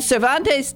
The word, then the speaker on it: Cervantes: And Cervantes, did I pronounce that right? Is that the way? I mean, that's the way I pronounce. – You got that right.